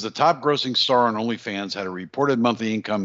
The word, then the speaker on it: OnlyFans: She has one of those OnlyFans. Did I say it right? – OnlyFans. – Yes, you did.